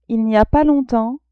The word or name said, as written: il n'y a pas longtemps